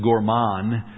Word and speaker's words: gourmand: And I'm sure a French major could correct me, but I believe it's gourmand. I think you don't pronounce the D at the end. It's silent, but G-O-U-R-M-A-N-D.